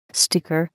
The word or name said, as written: sticker